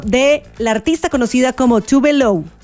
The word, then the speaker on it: Tove Lo: Se llama Tove Lo, o sea, Tove Lo, es la pronunciación, pero su nombre real es Eva Tove Elsa Nilsson.